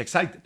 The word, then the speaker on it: Exide: Dentro se escribe Exide, se pronuncia Exide.